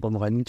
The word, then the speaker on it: Omrani: Omrani, tu l'écris Ah A-Y-C-A-L, plus loin, Omrani, O-M-R-A-N-I. Eh ben, Al, c'est comme ça qu'on prononce. Merci.